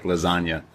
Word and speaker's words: lasagna: real G's move in silence like lasagna. Lil Wayne. – Lasagna's silent? Is it? – There's a silent G in lasagna.